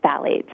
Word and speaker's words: phthalates: I want to talk about orthophthalates, and how do you actually say that? – It's phthalates. Yeah.